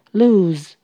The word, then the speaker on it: lose: Lose 遗失，它是一个动词，请留意它的发音是发 u 的长音。 Lose lose，它不是 loss。